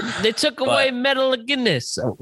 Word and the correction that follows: Metalingus: How do you pronounce that word? – Well, yeah, they took Metalingus. Metalingus.